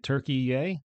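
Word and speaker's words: Türkiye: And to think of 40,000, If you thought I pronounced turkey wrong, calling it turkey-yay, you'd be wrong. Turkey-yay has gone back to their original spelling and pronunciation of their country.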